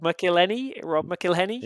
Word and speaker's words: McElhenney: McElhenney sort of. Is that how you pronounce it? I don't know how you pronounce it. – Rob McElhenney? – Yeah.